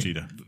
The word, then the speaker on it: Vegeta: Vegeta.